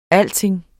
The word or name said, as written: alting